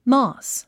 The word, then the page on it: moss: mɔs; モス